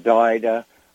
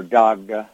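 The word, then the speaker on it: Daghda: And they would have the Daida, or Dagda, as it's sometimes mispronounced.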